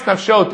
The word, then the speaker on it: nafshot: The misoret is even though it's pronounced nafshot, it's written in the singular form.